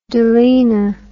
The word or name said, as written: doline